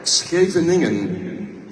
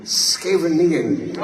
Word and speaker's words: Scheveningen: Scheveningen. – Scaveningen.